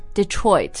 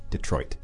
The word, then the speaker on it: Detroit: Detroit. – Mm-hmm, yeah, it's a D-E at the beginning, but we go really quickly through that and say Detroit. – Detroit.